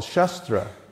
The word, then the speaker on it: shastra: And the other way is called shastra. It's just same spelling, only short A, and that means the sword.